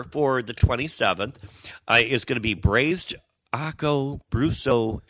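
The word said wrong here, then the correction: ossobuco: appetizer. Aco Brusso? – Aso Buco. Aco, Aco what? Aso Buco. Asobuco. Is that how you pronounce it? Good job.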